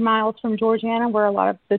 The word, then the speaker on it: Georgiana: Georgiana.